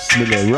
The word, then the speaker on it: Smille: I will say my name Smille, that the people understand it. Me no smile, we love to smile, but my name is Smille, Ruffneck-Smille.